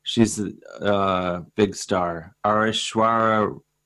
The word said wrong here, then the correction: Aishwarya: You want to get Aishwarya Rai to play the lead? – Yes.